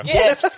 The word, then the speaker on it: GIF: GIF. GIF. Get it right. – GIF and banana. – Pronounce it correctly.